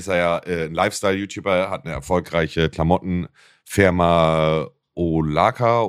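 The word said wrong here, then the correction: Olakala: Okaloka, ich kann das immer nicht Ola aussprechen, Mann. Ola Kala. – Ola Kala, genau, richtig, ja.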